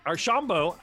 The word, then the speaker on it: Archambault: Archambault, I said it.